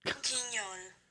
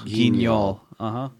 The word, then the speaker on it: Guignol: Its name, which is pronounced... God damn it. – Guignol, French. – Guignol. Hold on. Grand Guignol, French. – Guignol? – Guignol, uh-huh.